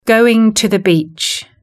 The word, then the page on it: going to the beach: British English